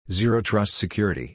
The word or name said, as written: Zero Trust Security